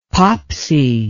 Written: popsy

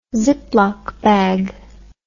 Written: ziploc bag

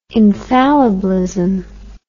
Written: infallibilism

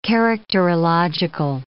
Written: characterological

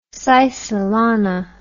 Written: sisalana

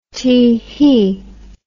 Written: tee-hee

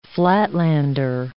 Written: flatlander

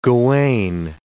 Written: Gawain

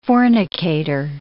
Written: fornicator

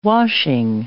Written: washing

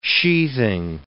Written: sheathing

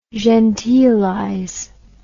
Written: genteelize

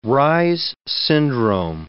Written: Reye's syndrome

